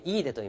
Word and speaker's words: 飯豊: では山地、朝日山地、イーデ、これね、飯の豊かと書いてイーデと読みます。